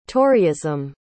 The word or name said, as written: toryism